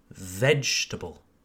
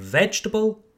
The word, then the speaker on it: vegetable: Okay, forget the second E, vegetable. And what does the word vegetable have in common with the words chocolate, Christmas, and comfortable? In the word vegetable, you emphasize the first syllable, vegetable. Let's practice.